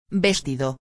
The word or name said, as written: vestido